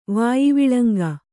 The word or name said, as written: ವಾಯಿವಿಳಂಗ